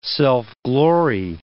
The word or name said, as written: self-glory